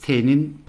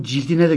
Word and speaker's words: Cildin: Orada Mukaddes T'nin parçalanışı olarak telaffuz ettim. Yeri gelmişken özür dileyeyim. Burada kitaba, kitabın cildine de gönderme var. Mukaddes Cildin Parçalanışı.